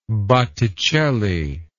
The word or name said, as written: botticellian